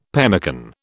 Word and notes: pemmican: British English

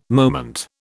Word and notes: moment: British English

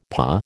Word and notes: pas: American English

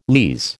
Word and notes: lees: American English

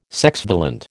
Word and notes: sexvalent: American English

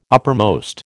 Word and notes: uppermost: American English

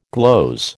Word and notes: gloze: American English

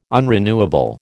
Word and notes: unrenewable: American English

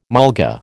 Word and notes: mulga: American English

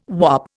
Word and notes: whop: Indian

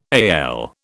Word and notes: AL: British English

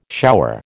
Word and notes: shower: American English

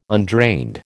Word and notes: undrained: American English

